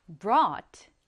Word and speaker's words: brought: 얘 발음이 조금 어렵죠? brought. 같이 따라해 볼게요. brought.